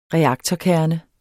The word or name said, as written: reaktorkerne